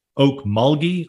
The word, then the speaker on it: Okmulgee: And I see here that, and I want to make sure I get the name right, is it Okmulgee, Oklahoma? – Yes, sir. That's the capital of our tribe.